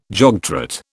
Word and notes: jogtrot: British English

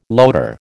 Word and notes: loader: American English

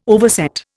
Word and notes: overset: Indian

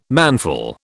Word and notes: manful: British English

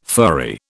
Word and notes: furry: British English

